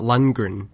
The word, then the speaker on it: Lundgren: Now, I can never say his name right. I always want to say Lundgren, not Lundgren.